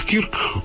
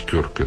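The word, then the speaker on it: Kirk: Вот Рональд Кирк, которого, ой, извините, Кёрк, он правильно произносится, который, я привык его Кирк произносить,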